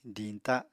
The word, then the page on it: diñta: apatani